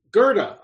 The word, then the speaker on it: Goethe: Well, and also there's, when I read that quote from Goethe, I don't know if I said it right, Goethe? – Goethe.